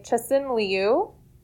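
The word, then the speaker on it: Cixin Liu: That's my best try at, that's how they pronounce the name of the author. It's C-I-X-I-N-L-I-U.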